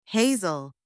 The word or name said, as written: hazel